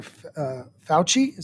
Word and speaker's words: Fauci: But somehow, I think Dr. Fauci, is that how you pronounce his name? – Yes.